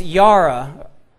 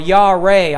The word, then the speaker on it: yara: In Exodus chapter 20 and verse 20, the first instance of the word fear I found is yara, or yareh, I believe as it's pronounced, spelled Y-A-R-A, to fear, to revere, cause to frighten, affright, be or make afraid, dread, or put in fear.